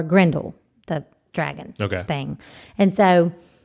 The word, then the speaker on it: Grendel: So what they did was they did some projects where you had to create, you know, Beowulf or Grendelin.